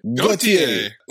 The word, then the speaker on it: Gauthier: Gaudier? I don't know how you say it. – Gauthier. Like the song.